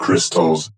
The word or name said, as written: Chrystals